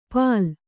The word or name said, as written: ಪಾಲ್